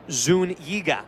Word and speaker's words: Zuniga: G-H-E-E, and then the dash, Yo. Y-O. And then the last name, Zuniga. Zun, Z-O-O-N. And then Y-E-E, here's the pitch, popped up first base side out of play, 1-2. And then Gah, G-A-H. So that's a broadcaster 101 right there when you have a pronunciation guide for an interesting name for the English language on a broadcast.